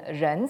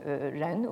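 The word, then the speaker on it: ren (仁): Donc ici, vous avez, en fait, dans la graphie même de ce terme, qui se prononce en chinois moderne « ren », c'est-à-dire de la même façon que l'être humain, donc « ren » aussi,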